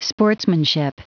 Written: sportsmanship

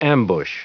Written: ambush

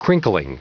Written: crinkling

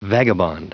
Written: vagabond

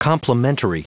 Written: complimentary

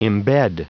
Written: embed

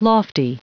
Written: lofty

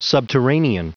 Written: subterranean

